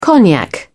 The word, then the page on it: cognac: British English